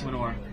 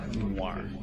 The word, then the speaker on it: Manoir: Manure. – Manure. – Manure. – Manoir. I thought it was manure for a second. Manoir.